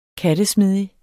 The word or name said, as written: kattesmidig